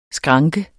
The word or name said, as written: skranke